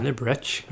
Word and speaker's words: Liberec: Which way would you pronounce the C? – Liberec. – Liberec, that'll go. We'll go with that. And all the guns.